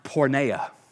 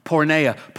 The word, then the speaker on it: porneia: It is pronounced porneia. porneia.